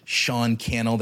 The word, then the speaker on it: Cannell: you could travel back in time and give Sean Cannell, that rhymes with YouTube channel, some wisdom, some advice, some encouragement to keep going.